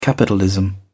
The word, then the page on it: capitalism: British English